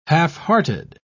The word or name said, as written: halfhearted